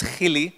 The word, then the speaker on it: Gielie: My name is Gielie, but real English people can't pronounce or say Gielie, so they call me Michael.